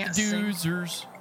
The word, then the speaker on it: Doozers: Dozers. But they're not going to mention that. They don't mention them in that. – Oh, well, don't forget about the Doozers, because that's how it's pronounced.